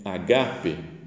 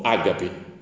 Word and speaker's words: ágape: E outra que é muito mais parecida com o português, que é agape o amor, o pessoal falou ágape. Ficou, fala, pra que, que mudar a sílaba? Ágape é super fácil de falar, né?